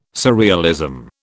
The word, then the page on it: surrealism: British English